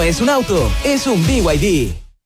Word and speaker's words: BYD: Se escribe BYD, pero se dice BYD.